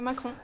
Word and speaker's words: Macron: Emmanuel Macron.